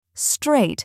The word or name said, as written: strait